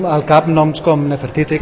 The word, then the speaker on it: Nefertiti: O sigui, una cosa és la dona de la reialesa, que tenim alguns noms com aquests que els has pronunciat perfectament, Hatshepsut, Nefertiti, Nefertari, etc, etc.